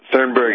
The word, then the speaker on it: Thunberg: Yeah, I mean, he at one point said, Greta Thunberg has more education than most of you. I may have mispronounced her last name. – No, you're right.